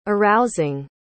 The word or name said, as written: arousing